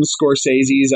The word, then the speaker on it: Scorsese: Because like you, I'm a fan of Martin Scorsese. Is that how you pronounce it? – Yes, yes. Good for you. – Scorsese. Okay, there we go.